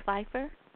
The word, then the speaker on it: Fiffer: Um, is your last name pronounced Fiffer? – Yes, it is.